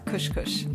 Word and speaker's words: KushKush: So if I'm pronouncing it right, is it KushKush? – That's right, KushKush.